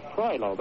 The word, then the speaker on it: Troilo: Ran right up the back of number 89, Troilo. That's the way he pronounced it. T-R-O-I-L-O. Troilo is the blocker.